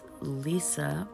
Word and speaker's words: Lysa: The author is Lisa, but it's spelled L-Y-S-A, Terkkerst, T-E-R-K-K-E-U-R-S-T, and that is a book that I'm very curious to read, because you know what?